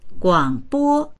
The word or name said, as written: guang3bo1